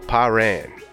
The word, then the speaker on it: Parrain: It's called Pyran, Do What I Gotta Do. For everybody that's north of the Mason-Dixon, that's spelled P-A-R-R-A-I-N.